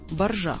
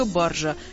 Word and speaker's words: баржа: Спасибо словарю ударений. В нем, как всегда, все просто и ясно. Только баржа. Я не вижу баржи.